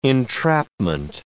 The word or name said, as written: entrapment